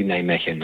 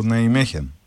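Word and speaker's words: Nijmegen: Ναι, βέβαια εγώ θα ήθελα να πω ότι θεωρώ ότι είναι καλή ομάδα η ΝΑΙΜΕΧΙΝ. Ή Ναϊμέχεν, όπως ο καθένας θέλει να την προφέρει. – Ναι, μου είπαν ότι είναι Ολλανδικό Ναϊμέχεν.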